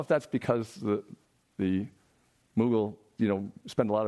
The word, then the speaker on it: Mughal: But it turned out that that's the British pronunciation of m- um, and the Americans say Mughal, and the, the Brits say Muggle.